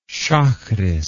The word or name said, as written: shacharith